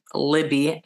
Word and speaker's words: Libby: Um, if you go to the Coney Island one and you want a discount, feel free to use a code Libby, L I B B Y, my name.